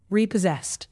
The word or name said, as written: repossessed